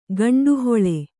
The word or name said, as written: ಗಂಡುಹೊಳೆ